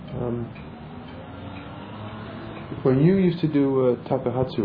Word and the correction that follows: takuhatsu: roshi, takahatsu, how do you say that? – Takuhatsu. – Takuhatsu, it's long year, takuhatsu.